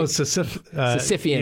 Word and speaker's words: Sisyphean: Oh, Sisyphean.